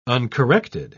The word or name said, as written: uncorrected